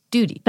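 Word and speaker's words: Doody: I don't think I did. It's D-O-O-D-Y. Duty. – That's Duty.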